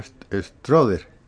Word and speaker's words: Stroeder: Ay, he tenido situaciones extremas en una población que se llama Stroder. Se escribe Stroeder.